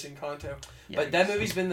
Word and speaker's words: Encanto: It's pronounced Encanto. It's Spanish.